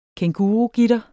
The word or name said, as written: kængurugitter